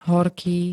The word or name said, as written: horký